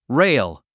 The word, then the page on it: rail: réil; レイル